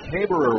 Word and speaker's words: Haberer: Haber is actually how it's pronounced. It's an interesting pronunciation. I knew I'd trip over it. The spelling is H-A-B-E-R-E-R, but the second E-R is silent. It is pronounced Haber, which is easier to say than Haberer.